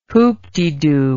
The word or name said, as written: whoopdedo